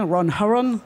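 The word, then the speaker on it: Ron Herron: Et très intéressant, le travail de donc Ron Haron, je ne sais pas si je le prononce très bien, Ron Haron, voilà, c'est meilleur.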